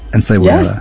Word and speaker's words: Encebollada: Carne frita, and let me see if I can pronounce this correctly. Encebollada. – Yes. – Encebollada. – Yes, you said it right.